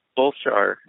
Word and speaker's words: Solskjaer: The new manager, is it Solskjaer? – Solskjaer. – Solskjaer. Yeah. So you got to work on your Norwegian pronunciation.